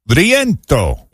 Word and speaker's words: rientro: It's spelled R-I-E-N-T-R-O, Il Rientro, if you can't roll your R's like I cannot.